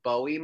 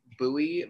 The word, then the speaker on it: Bowie: The first one I think it's absolutely hilarious that more than one person called me out on this, was it's actually not pronounced Bowie, Maryland. It's Bowie, Maryland. Even though it's spelled, like the way that you see it when it's spelled, you think that it's Bowie, you know, you think a Bowie knife or David Bowie or whatever. But no, they pronounce it Bowie.